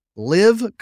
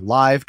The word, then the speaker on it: LIV: Some of the most recognizable names from the PGA Tour will be missing from this weekend's Canadian Open as the Saudi-backed LIV Golf Invitational Series, or Live Golf Invitational Series, depending on your pronunciation, makes its debut in London, England.